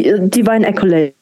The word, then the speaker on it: Divine Accolade: Ich würde schon gerne mal Richtung Divine, wie spricht man es überhaupt korrekt aus? – Die, Divine Accolade.